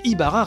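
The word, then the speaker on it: Ybarra: Euh, bref, deux semaines après avoir annoncé son départ, l'ancien corporate vice-président de Xbox, Mike Yabara, on en avait parlé la semaine dernière, je crois que j'ai dit Yabara tout à l'heure, ou Ibara, bref, excusez-moi.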